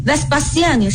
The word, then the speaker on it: Vespasienne: 大家听我念 Vespasienne 是不是跟我们这个韦斯巴乡这个发音是一样的？